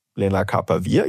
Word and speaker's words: Lenacapavir: Lenacapavir, ja, habe ich richtig gesagt.